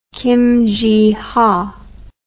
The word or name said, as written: kim ji ha